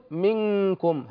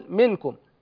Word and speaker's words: منكم: فحينما نقول مثلا منكم، لاحظ معي هنا النون جاء بعدها الكاف، والكاف من الحروف 15، أي النون تخفى عند الكاف، فنقول منكم هكذا منكم، لا نقول منكم لأن هذا يعتبر إظهارا، ولا نقول منكم لأن هذا يعتبر إدغاما، وإنما ننطق بالنون بحالة بين الإظهار والإدغام كما ذكرنا.